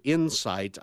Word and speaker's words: Incyte: So so that stock, it's Insight, again, symbol I-N-C-Y, and it's spelled Insight, I-N-C-Y-T-E, and that one's a biotech.